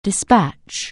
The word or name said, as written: dispatch